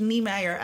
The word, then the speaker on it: Nijmeijer: Maar Volgens mij schrijf je Nijmeijer, maar spreek je Niemeyer uit.